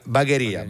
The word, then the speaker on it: Bagheria: Poi dopo Brescia vai a Padova, Bologna, Genova, Francavilla al Mare, c'è Roma, Salerno, Cosenza, Catania, Bari, Bagheria o Bagheria, non ho idea Bagheria della pronuncia. – È in Sicilia, fuori Palermo. Ok, sì, Bagheria.